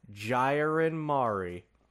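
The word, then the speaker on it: Gyarin Mari (계란말이): I think it's Jai. Gyarin Mari. Yes. Boom. Nailed it.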